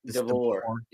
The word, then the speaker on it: DeBoer: Yeah, DeBoer.